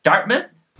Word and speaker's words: Dartmouth: And Jesse Cassana of Dartmouth College, Dartmouth, I believe it's pronounced.